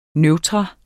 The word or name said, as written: neutrum